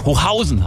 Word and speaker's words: Hochhausen: Ina schreibt hier noch, hallo, ich bin in dem kleinen Ort Hochhausen im Taubertal aufgewachsen. Im Gegensatz zum Gebäude wird aber die zweite Silbe betont. Also Hochhausen heißt es eigentlich.